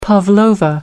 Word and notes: pavlova: American English

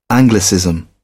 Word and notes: anglicism: British English

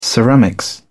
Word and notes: ceramics: British English

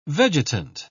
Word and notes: vegetant: British English

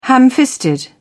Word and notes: ham-fisted: British English

